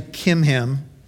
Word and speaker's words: Chimham: Actually, you don't pronounce the CH. His name is actually Chimham.